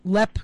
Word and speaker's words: Leap: A fun thing from there is you leave the grounds, you make a right turn until you come to Lepp Castle, which is spelled like Leap, L-E-A-P, but it's pronounced Lepp.